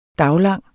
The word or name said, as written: daglang